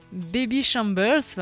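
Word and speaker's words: Babyshambles: le meneur des Bobby Chambler. Alors, je ne sais plus exactement comment Patrice m'avait dit qu'on pouvait le prononcer. Baby Chambers, voilà.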